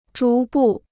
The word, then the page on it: 逐步: zhú bù